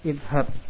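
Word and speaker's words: Idhab (اذهب): Idhab dibacanya fathah karena dari yadhabu. Yadhabu sehingga menjadi idhab.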